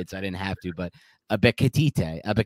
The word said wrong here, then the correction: Ebiketie: It's just Arnold Ebiketie. – Ebiketie, that's easy.